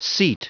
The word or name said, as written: seat